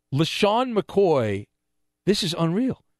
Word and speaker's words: LeSean: His given name is L-E-S-H-A-W-N. He goes by L-E-S-E-A-N. How odd is that? Like, his name it's pronounced the same, but he spells it differently than his birth name.